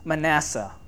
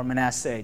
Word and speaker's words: Manasseh: The first king was Manasseh, or Manasseh, depending how you pronounce it.